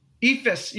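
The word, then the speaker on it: Eephus: Dude, that's the game he threw that ether pitch to Chase Utley, and he swuck out like an idiot. Wait, how do you say that name? Ether? What is it? – Ephus.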